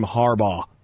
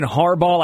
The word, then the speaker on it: Harbaugh: Jim H., getting the I sound like I'm saying Harbaugh, like Haribo, like toys, you know.